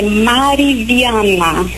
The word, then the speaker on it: Mariviana: Como é seu nome? Falei certo? – Olá, padre. O meu nome é Mariviana. – Mariana, tá certo, Mariana. – Não, Mariviana.